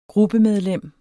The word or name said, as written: gruppemedlem